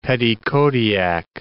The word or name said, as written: Petitcodiac